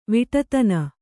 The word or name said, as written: ವಿಟತನ